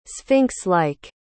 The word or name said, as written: sphinx-like